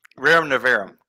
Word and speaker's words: Rerum Novarum: Rerum Novarum. – Rerum Novarum.